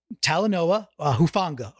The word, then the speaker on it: Talanoa Hufanga: And I looked the dude up, number 29 on the Niners, Talanoa Hufanga, a rookie from USC.